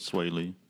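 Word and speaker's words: Swae Lee: Well, Sway Lee and Slim Jimmy will be in town. Is that how you say it, Sway Lee? – That's right.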